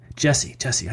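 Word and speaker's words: Gyasi: Gassi. Is that how you say it? Jesse. I know I keep messing that up.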